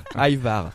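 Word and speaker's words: ajvar: Et ben, moi, j'ai eu l'occasion de le faire une fois pour faire une transformation, le hachevar, là, qui est une espèce de purée de poivron et aubergine. – Hachevar. Excuse-moi, c'est la prononciation. – Aïvar.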